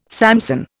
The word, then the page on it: Samson: Indian